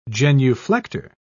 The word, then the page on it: genuflector: British English